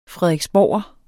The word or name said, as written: frederiksborger